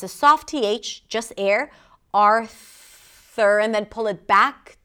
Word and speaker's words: Arthur: There is no U sound, even though it's spelled with a U, there is no U, Arthur. It's called a schwa. And you can learn more about it if you click the link in the show notes, in the description. Arthur. Primary stress is on the first syllable.